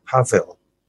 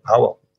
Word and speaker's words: Pavel: And I wrote this ironically titled Comrade Pavel, as you correctly pronounced it. Most people say Powell.